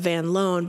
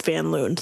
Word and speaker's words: Van Loon: And I read this whole thing where it was addressed, and he said that he still pronounces it in the Dutch way of Van Loon, but that he assumed that his kids would probably at some point go with Van Loon.